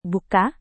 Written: buka

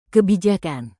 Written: kebijakan

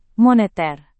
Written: moneter

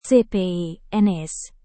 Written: cpns

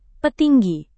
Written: petinggi

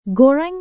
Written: goreng

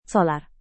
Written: solar

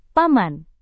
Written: paman

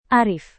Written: arif

